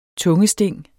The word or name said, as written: tungesting